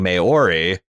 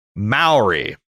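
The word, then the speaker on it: Māori: but can I point out the correct pronunciation of the word, what I've been saying, Maori, is Maori. Mao is in Chairman Mao.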